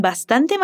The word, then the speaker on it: bastante: Y el chico quiere su sándwich con bastante mayonesa. Repite, con bastante mayonesa. Ahora vamos a ver su pronunciación. – Facundo también aspira la S al decir bastante. Queda así, Bastante.